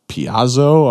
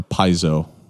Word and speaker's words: Paizo: are you guys them Piazzo? I'm like, what? A Piazzo? No, that's over there. No, so, you know, Paizo, which took a while for people to grok.